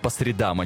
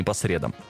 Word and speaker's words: по средам: Вот лично я в свое время при помощи этого стихотворения запомнил, что правильно говорить «по средам», а не «по средам».